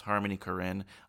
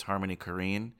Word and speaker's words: Harmony Korine: I always thought it was pronounced Harmony Korine. Apparently people are telling me it's Harmony Korine.